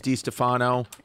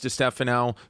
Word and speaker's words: DiStefano: Even in my own family, like my father says DiStefano and his sister says DiStefano.